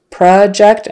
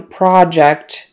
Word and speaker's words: project: Project is the verb. I need to project my handout on the wall so everyone can see it. Okay, project. Project, and again, since the first syllable is no longer stressed in the verb, the vowel turns into kind of a schwa, a short U. Project. I need to project my handout versus project. And notice that in the noun project, it's not project with a long O, it's project. Have you completed your project?